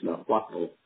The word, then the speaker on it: Nahuatl: And actually in the Indian language of, I believe it's pronounced Nahuatl, which is the indigenous Indians of Central America, they call it turtle rabbit.